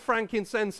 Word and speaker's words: frankincense: Gold, frankincense, and myrrh. That's right. Well pronounced as well, because normally it all goes a bit wrong there, doesn't it? You get Frankenstein and a mulled wine or whatever. But that's right.